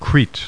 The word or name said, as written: CRETE